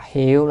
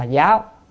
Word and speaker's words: giáo: Đó chữ giáo này á, có 3 âm là giáo, là hiệu, là hào. Ở đây phải đọc là âm là giáo. Hòa thượng có chú giải luôn phải đọc âm nào.